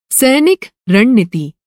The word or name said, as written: रणनीति